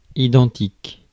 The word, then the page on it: identique: France; [i.dɑ̃.tik]